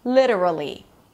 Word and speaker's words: literally: It's a secondary stress. So it's going to be a little longer than the previous two syllables, the two schwas, literally. Ta-da-ta-ta. Literally.